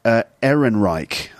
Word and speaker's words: Ehrenreich: Also, there were doubts about the ability of the actor, Alden, how do you say his name? Ehrenreich, I think that's how you say it.